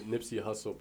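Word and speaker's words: Nipsey Hussle: Wait, I said that right? – You said Nipsey Hart. – Yeah, that's what I thought I said. This Nipsey Hustle Park, and he said that it was so many people, there's so many photographers, people balling up crazy and shit like that.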